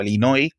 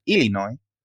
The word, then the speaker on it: Illinois: porque Illinois, eh, o Illinois, como lo pronuncian allí, creo que la S la, cortan un poco, la pronunciación local, Illinois, había abierto una investigación, no recuerdo ahora mismo, ¿no?